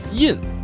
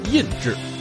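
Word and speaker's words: 窨: 这个字在字典里有两个读音，印表示地印子、地下室，而另一个发音熏，意思是让茶叶染上花香。但是茶叶界行话管这道工序叫印制，也就有了印得茉莉无上味，列作人间第一香的老话。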